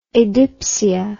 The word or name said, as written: adipsia